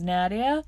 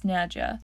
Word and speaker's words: Nadja: He mispronounces her name as Nadia, and she corrects him and says it's Nadja.